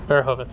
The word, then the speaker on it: Verhoeven: Yeah, Paul Verhoeven. I butchered that. – Yeah, Verhoeven. – Verhoeven.